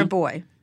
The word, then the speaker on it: Rabois: Rabois.